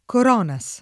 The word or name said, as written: Coronas